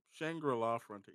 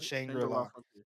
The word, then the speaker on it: Shangri-La Frontier: But if you haven't yet, get caught up on Shangri-La Frontier. – Yes. – I butchered it, didn't I? – No. Well, Shangri-La Frontier. Yeah, no, you got it. – Shangri-La Frontier.